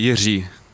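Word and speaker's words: Jiří: No, it's actually Jiri. – Jiri. – Yeah, exactly. We are the only country in the world that can pronounce this letter R, and everyone struggles, so it's fun for me to how they struggle and hear the different version of my name.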